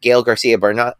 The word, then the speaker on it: Gael García Bernal: Cassandra, which I didn't know existed, but it's about a real-life luchador, but it's a fictionalized retelling with Gael García Bernal.